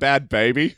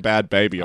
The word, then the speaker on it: Bhad Bhabie: I thought you were talking about Bad Baby. Do you remember Bad Baby? – No. – So it's spelled B-H-A-D-B-H-A-B-I-E.